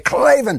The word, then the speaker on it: Klavan: You say Klavan. And you got to spell it right, too, by the way. It's K-L-A-V-A-N.